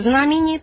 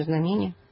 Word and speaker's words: знамение: Да, так же, как и знамение по церковно-славянски, а мы говорим знамение.